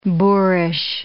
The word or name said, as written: boorish